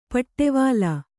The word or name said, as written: ಪಿಡಿವಲೆ